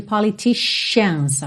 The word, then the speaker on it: politicians: Atenção aí também à pronúncia de politicians, ok? Com esse sh, sh, aí.